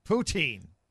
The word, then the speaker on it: poutine: Poutine. – All right. – Picture poo. – Poo? – Teen. Like T-E-N. That's how it's pronounced. Poutine.